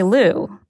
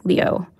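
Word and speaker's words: Liu: And even the way I introduce myself, usually my last name, I say Lu. But that's not how you actually pronounce it. That's the anglicized version. How you really pronounce it is like Leo.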